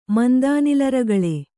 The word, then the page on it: ಮಂದಾನಿಲರಗಳೆ: mandānila ragaḷe